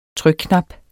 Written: trykknap

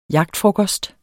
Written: jagtfrokost

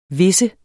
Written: visse

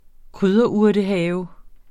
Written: krydderurtehave

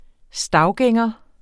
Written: stavgænger

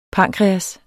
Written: pankreas

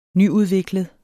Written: nyudviklet

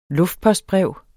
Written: luftpostbrev

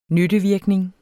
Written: nyttevirkning

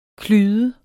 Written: klyde

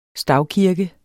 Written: stavkirke

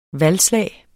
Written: valgslag

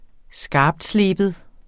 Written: skarptsleben

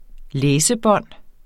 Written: læsebånd

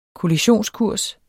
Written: kollisionskurs

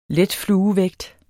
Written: letfluevægt

